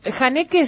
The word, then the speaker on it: Haneke: Todo bien, con ganas de escuchar tu comentario respecto de la película Funny Games del año 97, de, no sé si se pronuncia Haneke o cómo. – Haneke sí, lo que yo no sé, vos dijiste Michael recién, no sé.